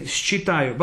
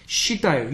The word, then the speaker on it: считаю: In normal life, we can say считаю, but nobody say it like that. Считаю. You should put считаю.